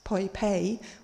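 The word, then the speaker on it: Poipet: which is in a city which you actually pronounce Poi Pei, although it's spelled Poi Pet.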